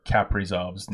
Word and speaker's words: Kaprizov: Oh, I'm getting shit for pronouncing Kaprizov's name wrong. Kaprizov? C'est la vie.